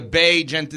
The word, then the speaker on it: Bagent: I think I did because Jonathan Hood kept tweeting out yesterday, Bay. Like B-A-E. So yeah. I figured, okay, it must be a Bay Gent. That's how you say it.